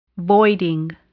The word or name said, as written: voiding